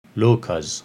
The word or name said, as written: Lokas